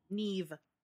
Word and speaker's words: Niamh: Neve. Oh, you pronounce that as a V, huh? – Yeah, it's a Welsh name.